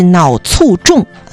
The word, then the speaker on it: 卒中: 有大量的研究显示，这个高同型半胱氨酸血症与脑卒中风险成正关。其实这个脑卒中啊，是大家平时的一个念法，它的标准读音应该是脑卒中。